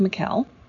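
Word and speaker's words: Michaele: My first name is spelled like Michael, but with an E at the end, but it is pronounced Mikhail.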